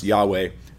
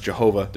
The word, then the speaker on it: Yahweh: So it may have been pronounced Yahweh. Definitely was not pronounced Jehovah. There's no J sound. Actually, in Hebrew, so we're pretty sure that they didn't make a sound they don't have, but maybe Yahweh.